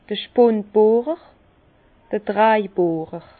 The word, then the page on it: d'r Spundbohrer, d'r Draibohrer: Alsatian; Bas-Rhin; Herrlisheim